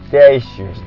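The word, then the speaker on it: station: ステーション。 – ステーション。 – ステーション。 – T-I-O-N がちょっと下噛む感じでね。ステーション。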